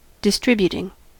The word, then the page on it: distributing: American English